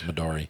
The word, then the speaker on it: Midori: Am I saying it wrong? – M-I-D-O-R-I. Midori. Or Midori. I don't know. To me, it's Midori.